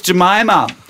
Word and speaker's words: Jemima: So will ich sie aussprechen. Jemima wird's geschrieben. Wir haben vorhin mal nach US-amerikanischen Aussprachen im Netz geforscht. Die sagen alle Jemima. Alice kommt aus England. Vielleicht sagt man da auch Jemima.